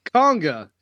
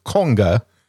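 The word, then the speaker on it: Konga: The film we are going to talk about is 1961 sci-fi, I guess they categorize it as a horror film, Conga. – It's pronounced Conga. – Conga. – Conga. Sorry, yes, Conga.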